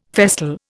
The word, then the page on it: festal: Indian